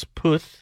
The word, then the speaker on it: Puth: I believe his name is Charlie Puth. – No, it's Puth. – Oh, well, then I've been saying it wrong.